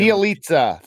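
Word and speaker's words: Bjelica: And the Mavericks couldn't find I can't even say his name. What is it? Belicia. – I'm not even gonna try. – He was awesome. That's my total whiteness coming out trying to pronounce his name. But yeah, he was great making his shots, but the Mavericks really didn't seem like they could find him. – Bielitsa. That's Bielitsa.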